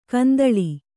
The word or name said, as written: ಕೆಂದಾಳೆ